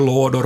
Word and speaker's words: lådor: Jag ska vara Pärna och mycket lådor på jul. Lådor med kort å.